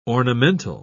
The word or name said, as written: ornamental